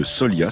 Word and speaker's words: Soliha: C'est Alison, ergothérapeute à l'association landaise de Solia, ça s'écrit S-O-L-I-H-A, qui me pousse à faire un rappel.